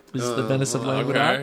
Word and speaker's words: Languedoc: The Venice of Languedoc, I'm sure I'm mispronouncing Languedoc.